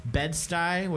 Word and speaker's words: Bed-Stuy: We're staying in Bed-Stuy, which I've heard I'm saying that right? – Yes.